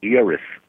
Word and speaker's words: Eris: Am I pronouncing your name right, by the way? – It's Uris. – E-R-I-S. Uris Dubois.